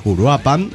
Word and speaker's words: Uruapan: Acentúes en la última sílaba pirata, Uruapan, ok.